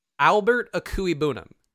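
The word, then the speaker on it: Albert Okwuegbunam: So I do know how to pronounce Albert Akui Bunum. – Albert Akui Bunum. – Yes. Okay. There are some words or letters in there that are silent, but that is the phonetic pronunciation.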